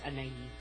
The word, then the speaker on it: Anais: Actually, if she's French, it's Anais. – Anais. – Yeah. – If it's French, it's spelled A-N-A-I-S.